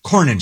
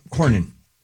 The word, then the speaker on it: Cornyn: Cornyn. – John Cornyn? – Yeah, Cornyn.